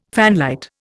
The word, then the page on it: fanlight: Indian